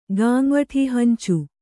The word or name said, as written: ಗಾಂವಠೀಹಂಚು